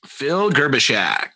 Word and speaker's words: Phil Gerbyshak: Phil Gerbyshak.